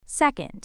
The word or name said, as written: second